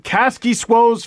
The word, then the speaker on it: Kaskisuo: She said, "Hey, Steve Dangle, I just met Kaski Suo's family. They're super sweet. And I asked how to pronounce his name. You're saying it right!